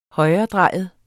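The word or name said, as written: højredrejet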